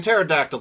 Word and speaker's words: pterodactyl: Like, you see, in pterodactyl, the P is silent, but the Japanese pronounce the P and don't pronounce the T, so it's pteranodon.